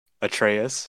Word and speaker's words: Atreus: Atreus. – I'm not going to missay his name. Atreus and Kratos are probably going to fight Thor.